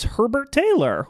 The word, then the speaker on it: Herbert-Taylor: Please welcome to the Gory Days, Paris Herbert-Taylor. Welcome. – Hello. – Is that how you pronounce it? – Yes. – Herbert-Taylor.